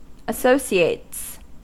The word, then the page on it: associates: American English